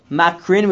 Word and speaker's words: Makrin: Granted, it's pronounced Makrin, which sounds like multiple horns, but spelled without the yud, with it more Mikran or something Mikaren, which is simply one horn.